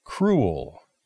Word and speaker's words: cruel: Mm, cruel. – Almost. The pronunciation is cruel. Cruel. It's the same word in Spanish, right? Cruel. We just pronounce it differently.